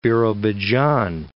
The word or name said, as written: Birobidzhan